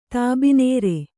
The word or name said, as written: ತಾಬಿನೇರೆ